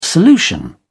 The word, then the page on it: solution: British English